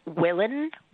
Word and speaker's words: willan: It's a word that we may pronounce today as willin, so W-I-L-L-A-N, Old English, used to mean to want, to wish.